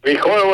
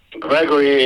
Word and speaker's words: Grigor: Grigor would be an Armenian pronunciation. Gregory is an American name.